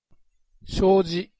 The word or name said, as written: shoji